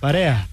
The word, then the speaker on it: Парея: Къде е ударението? Парея? Парея. Значи да, имах правилно усещание.